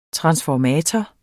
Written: transformator

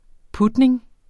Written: putning

